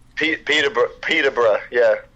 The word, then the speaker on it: Peterborough: Peterborough, yeah. It's kind of, it's spelled like borough, but we say it like bruh.